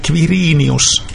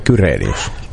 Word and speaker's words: Quirinius: Se on ihan niin kuin KV, Quirinius, hän äänsi itse nimensä. – Mutta miksi me sanomme Kyrenius?